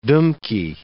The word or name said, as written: dumky